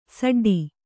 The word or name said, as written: ಸಡ್ಡು